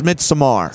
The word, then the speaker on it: Midsommar: When you said Midsummer, that's what I first thought of. – The way I pronounce it is Midsommar.